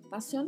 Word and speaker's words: pasión: Pasión que é um substantivo que significa paixão. E atenção para a pronúncia aí, que é som de S mesmo, tá? Pasión.